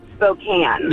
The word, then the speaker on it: Spokane: It's actually pronounced Spokane. – Yeah, it's Spokane, Washington. It's not Spokane.